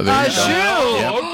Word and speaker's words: au jus: Oh, au jus. Okay. – You're even saying it right.